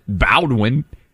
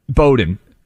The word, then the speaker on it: Bowdoin: This isn't Bowdoin. – Bowdoin. – I can't even pronounce it right.